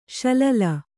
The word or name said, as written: ಸಲೀಲ